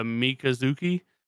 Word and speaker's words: Mikazuki: Yeah, and Mikazuki, if I say that correctly.